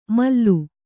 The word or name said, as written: ಮಲ್ಲು